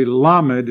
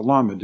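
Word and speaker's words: lamed (למד): Actually, lamed is the way they would pronounce it in the Hebrew. It's a lamed.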